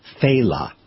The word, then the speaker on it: Failla: His name is pronounced Failla. Failla. It's spelled F-A-I-L-L-A, so people go Failla, Failla.